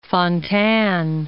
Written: Fontanne

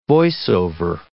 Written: voice-over

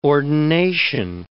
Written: ordination

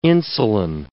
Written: insulin

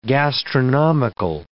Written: gastronomical

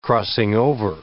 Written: crossing-over

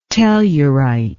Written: tellurite

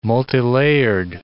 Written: multilayered

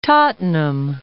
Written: Tottenham

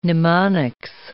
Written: mnemonics

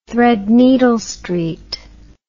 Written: Threadneedle Street

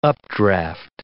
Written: updraft

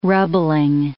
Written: rubbling

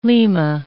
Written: Lima

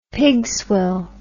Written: pigswill

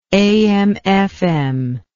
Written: am fm

